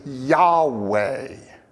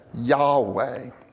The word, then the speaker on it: Yahweh: If they're all capitalized, that is to tell us that the word for God there is the one, and we don't really know how to pronounce it, right? It's the four-letter Hebrew name for God that we often say is Yahweh. Okay? Yahweh.